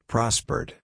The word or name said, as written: prospered